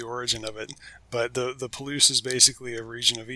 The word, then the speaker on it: Palouse: Palouse, I said it right? – Palouse. – Palouse.